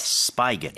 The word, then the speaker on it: spigot: 근데 어디서 하냐면 어디에 있냐면 at a public spigot이라고 얘기를 하는데 is that a spigot or a spigot? – It's actually spigot. – Spigot. – It's spelled S-P-I-G-O-T.